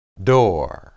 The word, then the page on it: dor: hebrew